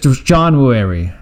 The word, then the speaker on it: Jonhwooary: Jonhwooary? I don't know if I said it right. – No, just Jonhwooary. Jonhwooary. It's a fluid word.